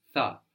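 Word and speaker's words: tha (θα): Now tha is spelled with that letter that looks like a zero with a horizontal line running through the middle, which gives us th, tha.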